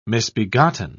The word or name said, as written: misbegotten